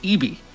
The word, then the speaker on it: Eby: First of all, I think it's pronounced Eby, at least in this province where he is Premier.